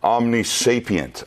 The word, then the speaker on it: omnisapient: Omnisapient, omnisapient. That refers to God's sapience. It's a word that refers to wisdom, skill, and biblically it refers to, did I say it wrong? – No.